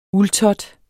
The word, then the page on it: uldtot: [ ˈul- ]